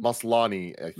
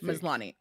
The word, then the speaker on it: Maslany: I thought that Tatiana Maslany, is that how you say her name? – Maslany, Maslany. Okay.